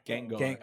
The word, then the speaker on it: Gengar: Gengar.